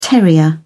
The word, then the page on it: terrier: British English